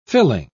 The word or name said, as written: fill-in